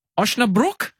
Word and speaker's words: Osnabrück: You have to fix my pronunciation here, Jacko. You're playing VFL Osnabrück? – Yeah. – How have I gone there? – Pretty good. Pretty good. Yeah, yeah.